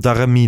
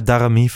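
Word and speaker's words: Daramy: Waarom komt Darami er niet in? Gewoon, je doet alleen maar Klaassen voor Berghuis. Spreek je zijn naam zo uit trouwens? – Ja, Darami volgens mij.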